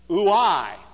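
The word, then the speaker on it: ouai (οὐαί): If I were to pronounce it in Greek, it would be something like ouai.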